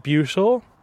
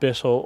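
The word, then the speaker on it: Buittle: That's the ancient sort of medieval pronunciation coming from a Norse or Old English word, Bothel. Mm-hmm. We pronounce it Bootle. A lot of the local people pronounce it Bittle or Bootle.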